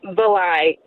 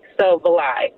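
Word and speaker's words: Varlai: So Valai. – So Valai. – I got the V. What's the rest of it? – Yeah. – Valai. – It's V-A-R-L-A-I, but the R is silent.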